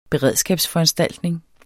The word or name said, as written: beredskabsforanstaltninger